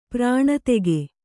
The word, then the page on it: ಪ್ರಾಣತೆಗೆ: prāṇa tege